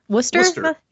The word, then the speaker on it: Worcester: So yeah, this episode, Fight for the Fallen, took place in, oh god, Worcestershire, Massachusetts? – Worcester.